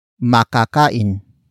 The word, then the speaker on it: makakain: And that is makakain. Makakain. Makakain. Makakain is spelled as M-A-K-A-K-A-I-N.